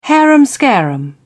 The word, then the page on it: harum scarum: British English